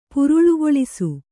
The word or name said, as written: ಪುರುಳುಗೊಳಿಸು